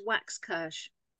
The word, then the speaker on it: Waxkirsh: It's exactly as it's spelled. It sounds as the way it's spelled. So it's Waxkirsh. That's it.